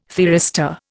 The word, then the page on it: thyristor: Indian